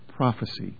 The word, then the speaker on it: prophecy: By the way, if you hadn't noticed this in Scripture, when prophesy is spelled with an S, that's how it's pronounced, prophesy. When it's spelled with a C, it's pronounced prophecy. Prophecy is a noun, prophesy is a verb, with the S as the verb.